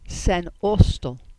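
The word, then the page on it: S. Austel: Cornish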